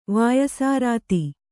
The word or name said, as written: ವಾಯಸಾರಾತಿ